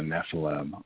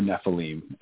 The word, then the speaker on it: Nephilim: I received actually a DVD documentary called The Nephilim, or Nephilim, either pronunciation is fine, The Nephilim Among Us.